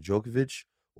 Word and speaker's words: Djokovic: The Australian Open winner will be Novak Djokovic or the field? – First of all, great pronunciation of Djokovic.